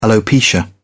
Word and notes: alopecia: British English

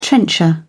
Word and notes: trencher: British English

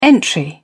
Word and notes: entry: British English

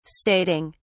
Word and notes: stating: British English

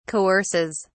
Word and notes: coerces: British English

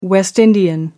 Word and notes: West Indian: American English

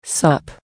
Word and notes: sup: American English

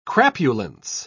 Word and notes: crapulence: British English

